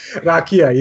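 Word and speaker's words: rakija: I think I'm most excited about the Radka at Belgrade. – Rakia, yes. – Rakia, yes. I always say it wrong.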